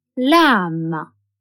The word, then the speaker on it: lamb: B is a silent letter in lamb, tomb.